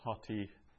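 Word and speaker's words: hoti (ὅτι): So it's a rough breathing there on the omicron, so we pronounce it with a H at the front, hoti.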